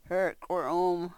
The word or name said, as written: hrkwrh u-m